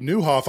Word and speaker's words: Nieuwhof: Why can't you read his last name? Because it's spelled N-I-E-U-W-H-O-F. It's got every vowel in it except for A.